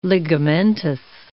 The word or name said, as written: ligamentous